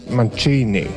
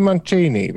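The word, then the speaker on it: Mancini: Nu, bet ļoti dīvaini, jo itāļu uzvārdu es tikko skatos vairākus izrunas veidus, un to izzināju Mancīnī. – Neviens cilvēks es... tajā Breakfast at Tiffany's pieliktajā dokumentālajā filmā neteica Mančīnī. Bet es domāju, mancīnī, tie bija varbūt mancīnī. itāļi, un tas bija viņa izruna, bet, bet pareizi ir Mančīnī vienalga.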